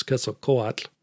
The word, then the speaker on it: Quetzalcoatl: The Aztec pronunciation is Quetzalcoatl, and I think my children are about the only ones that ever learned how to do it that way.